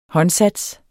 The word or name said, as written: håndsats